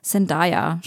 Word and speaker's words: Zendaya: Ja, featuring der Hauptdarstellerin Zendaya, spricht man sie, glaube ich, aus, oder? – Ja.